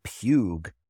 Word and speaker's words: Puig: David Puig, and positive because I finally got his name right.